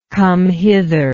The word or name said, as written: come-hither